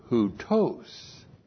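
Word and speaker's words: houtos (οὕτως): And then we have an adverb, houtos. H-O-U-T, long O-S, houtos.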